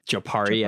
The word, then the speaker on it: Jopari: Like, I think that's a good point, because particularly Grumman or Jopri, mm-hmm. which is definitely not how I pronounced it in my head in the book. – Jopari. – I put more of the emphasis on the par.